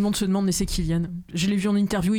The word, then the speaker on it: Cillian: Avec un C, mais ça se prononce Cillian.